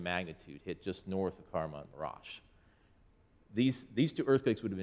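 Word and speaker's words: Kahramanmaraş: And then that name that everybody was struggling to pronounce is Karaman Maraş.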